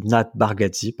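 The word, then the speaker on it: Nate Bargatze: Ah ben, tu sais, il y a, y a Jared, euh, Nat je prononce euh, plusieurs fois différemment le nom, mais c'est Nat Bargatze, pardon.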